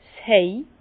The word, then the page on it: 's Hej: Bas Rhin; Herrlisheim